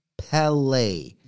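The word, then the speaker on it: Pelé: Well, that's called an acute accent, which indicates to you that's different to a normal E. Therefore, you should pronounce it differently. So it's Pele.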